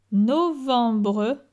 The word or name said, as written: Novembre